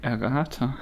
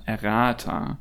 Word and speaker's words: Errata: dann werde ich das in unserer allgemein bekannten Rubrik Errata tun, ähm, die eigentlich äh, Errata heißen müsste. Das ist unser Fehler von der letzten Folge. Ähm, das Vermächtnis der Initialbetonung ähm, im Deutschen ist, dass ich das Wort so ausgesprochen habe, obwohl es ja, hat ja eine lateinische, hat, ist ja lateinisch eigentlich und ähm, auf der äh, Penultima betont, also äh, Errata.